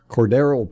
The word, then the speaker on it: Cordarrelle: Well, it seems like they've found in Cordell, how do you say his name? Cordarrelle Patterson, a hybrid running back wide receiver, and they using him.